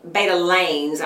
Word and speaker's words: betalains: Beetroots contain powerful anti-inflammatory compounds called beta beta lane, betalains, I think is how you how you say it.